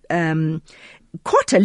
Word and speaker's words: kota: Okay, so what they do is kwotta literally means quarter. So it's spelled K-O-T-A.